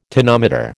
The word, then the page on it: tonometer: American English